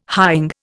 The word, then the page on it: hying: Indian